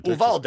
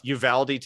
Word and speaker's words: Uvalde: And it is Uvalde. I've not been able to understand how to pronounce that name. – Uvalde. It's Uvalde, Texas. Uvalde, and it's Texas.